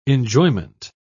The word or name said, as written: enjoyment